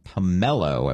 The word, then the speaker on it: Pamelo: Pamelo, I believe is how it's pronounced. P-A-M-E-L-O.